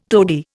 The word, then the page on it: tody: Indian